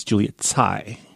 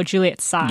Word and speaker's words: Juliet Tsai: I believe it's Juliet Tsai. – Oh, Juliet Tsai. – Yeah. – Sorry. – If we're going by Chinese pinyin.